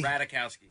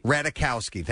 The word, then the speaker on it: Ratajkowski: Emily Ratajkowski. Is that how you say your name? – Emily. – Emily. – Ratajkowski. – Ratajkowski. Thank you.